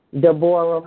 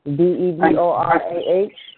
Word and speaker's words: Deborah: I'm Deborah Moore with the red flag with the green stars in it. One green star. – And what did you say your name was again? – Deborah Moore.